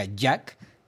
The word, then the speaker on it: JAC: Bueno, a ver, fíjense, vamos a hablar precisamente de esta marca Jack que se escribe J-A-C.